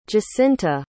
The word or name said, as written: jacinta